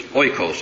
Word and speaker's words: oikos: In the Greek, it's oikos, if I'm pronouncing that right. It's O-I-K-O-S in the Greek.